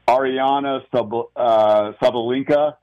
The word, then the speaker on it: Aryna Sabalenka: Open Women's Champion, and if I mispronounce it, I know you'll correct me, Aryna Sabalenka. – Yep, you got it.